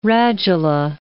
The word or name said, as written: radula